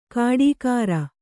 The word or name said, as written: ಕಾಡೀಖಾರ